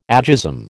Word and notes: agism: American English

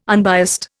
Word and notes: unbiased: Indian

